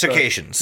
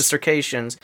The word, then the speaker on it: Circassians: Circassians. – The Circassians.